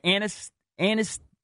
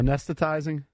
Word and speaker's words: anesthetizing: It's a non-narcotic, oral, anti-tussive cough suppressant drug, which works by anesthetizing. How do you say that word? – Anesthetizing. – Anesthetizing. I sound like Drabby.